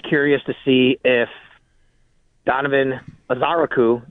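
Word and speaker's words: Ezeiruaku: Like, we went and looked at pronunciations, and it looks like the emphasis is on the second syllable from everything we saw, which is Azaraku. I'm sorry, Azaraku. See, there you go. I did it. Azaraku is how you say it.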